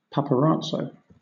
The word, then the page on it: paparazzo: British English